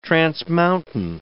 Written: transmountain

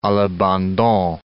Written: a l'abandon

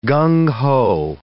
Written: gung ho

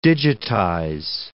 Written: digitize

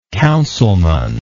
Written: councilman

